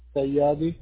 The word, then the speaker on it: Sayyadi: Sayyadi. – Can you spell that? – S-A-Y-Y-A-D-I. – S-A-Y-Y-A-E-I? – A-D-I.